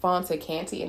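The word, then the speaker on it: Fanta Kante: You can also follow me on Facebook if you would like at Fonta Kante, and it's spelled F-A-N-T-A, last name K-A-N-T-E.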